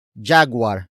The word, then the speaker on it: jaguar: The first word is jaguar. Jaguar. Jaguar. Jaguar is spelled as J-A-G-U-A-R.